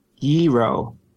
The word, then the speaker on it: gyro: Gyro.